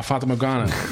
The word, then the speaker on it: Fata Morgana: I don't know how to pronounce it, but Fata Morgana. – Ah, Fata Morgana.